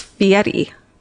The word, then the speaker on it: Fieri: But is that how he pronounces it? – I think it's Fieri, Stephen. – But no one anywhere else says that, though.